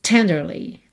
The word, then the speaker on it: tenderly: Normalmente, diríamos tenderly. Tenderly, sílaba tônica é a primeira.